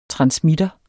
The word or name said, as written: transmitter